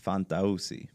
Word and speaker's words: Fantauzzi: Fantauzzi. – I'll never be able to do that. – Yeah. So the easiest way is Fanta, like the soda, with a little extra on the A, and Uzi, like the gun.